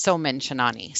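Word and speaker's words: Chainani: I'm probably pronouncing that completely wrong, but I believe that's correct. Oh, she thinks it's Soman Chinani.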